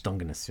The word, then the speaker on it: Dungeness: And Kathy got a Dungeness crab, which I referred to during the entire trip as a dungeon crab. – Dungeon crab. – And I think it's a Dungeness crab. It is how it's pronounced. – Dungeness? – Dungeness, yes.